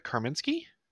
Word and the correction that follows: Kaminsky: Am I saying her name right? – Ava Kaminsky. – Kaminsky. – Okay. I don't think there's R. – No, there is no R.